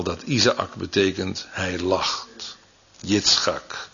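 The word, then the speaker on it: Yitzchak (יצחק): Dat is de Hebreeuwse uitspraak die bij ons een beetje verbasterd is tot Isaac of Isaak. Maar de Hebreeuwse werkwoordsvorm is Yitzchak.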